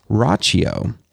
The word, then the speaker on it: Rachio: Rachio, that's R-A-C-H-I-O.